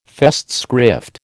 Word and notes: Festschrift: American English

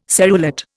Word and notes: serrulate: Indian

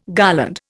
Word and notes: garland: Indian

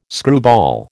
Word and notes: screwball: American English

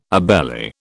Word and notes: obeli: British English

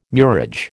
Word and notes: murage: American English

